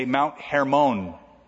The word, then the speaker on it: Hermon: But so that you don't get that confused with Hermon Munster, we should pronounce it the correct way, as the Jewish people say, Mount Hermon.